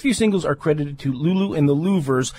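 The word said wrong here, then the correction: Luvvers: It's pronounced Lovers? – Lovers, yes. Okay. I know it's a weird spelling.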